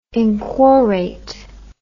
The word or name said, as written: inquorate